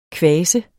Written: kvas

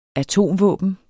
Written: atomvåben

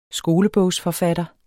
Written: skolebogsforfatter

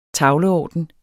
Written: tavleorden

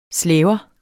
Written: slæber

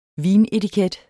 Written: vinetiket

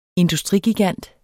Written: industrigigant

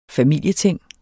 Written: familieting

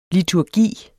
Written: liturgi

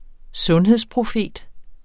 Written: sundhedsprofet

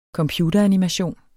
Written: computeranimation

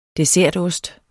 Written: dessertost

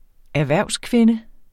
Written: erhvervskvinde

